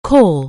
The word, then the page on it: CALL: anglais